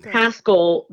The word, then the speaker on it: Pascal: We're going to get to Pashal. – It's Pascal that rhymes with rascal.